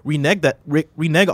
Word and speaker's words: renege: Did Activision Blizzard renege on a deal, or did NVIDIA fail to get permission? – Even though renege is spelled incorrectly here, you got that exactly right.